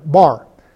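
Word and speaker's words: Bar (בר): Pronounced Ben. Oh, I'm sorry, Bar.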